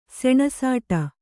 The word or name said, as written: ಸೆಣಸಾಟ